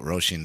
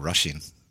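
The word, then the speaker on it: Róisín: We we call it Roisin down here, but in up north they call it sort of Russian.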